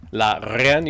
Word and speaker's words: La Reine: La Reine, you just gotta roll your R's.